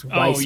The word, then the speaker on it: Weiss: That's probably what that pronunciation they were trying to get me to say, which was Weiss beer. – Oh, yeah. That weird B-looking thing is pronounced like two S's.